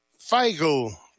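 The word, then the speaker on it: Feigl: Brady Figel, Figel, para correcta pronunciación.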